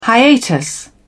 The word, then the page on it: hiatus: British English